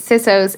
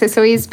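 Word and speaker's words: Sisoes: So we're celebrating Saint Sissos. It might be pronounced Sissoes, but I prefer Sissos.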